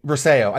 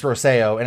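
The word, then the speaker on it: Rocío: But it's Rosseo, and it's spelled differently than I expected, but I actually really like how it's spelled. – Mm-hmm. – Which is R-O-C-I-O, I think.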